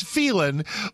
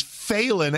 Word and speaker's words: Phelan: Well, it's Phelan, but it is Phelan as in F-A-I-L-I-N apostrophe if you are Lieutenant Governor Dan Patrick.